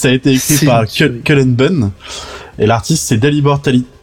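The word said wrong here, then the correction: Talajić: Oh, j'arrive pas à le prononcer, je suis désolé s'il nous écoute. L'artiste c'est Dalibor Taladjit.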